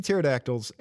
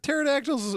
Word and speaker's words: pterodactyl: They got pterodactyls in other places, I guess. What? Maybe in the other planets they pronounce the P.